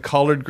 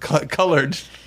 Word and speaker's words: collard: He didn't know that collard greens. He thought they were pronounced colored. Colored greens.